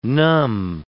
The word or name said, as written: numb